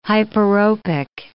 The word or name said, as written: hyperopic